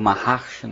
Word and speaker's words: Maharshi: Voltando, agora, um outro texto do Maharishi, ou Maharshi, né? Pronunciando corretamente o nome do nosso filósofo aqui, que eu vou explorar mais adiante.